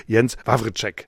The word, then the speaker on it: Jens Wawrzeck: Wenn wir alles bereden können, erstmal freue ich mich sehr, dass du Zeit für uns hast und Glückwunsch natürlich zu deinem Buch, Jens Wawrzeck. Und so spricht man ihn richtig aus, das habe ich jetzt endlich mal gelernt. – Wunderbar, wunderbar ausgesprochen.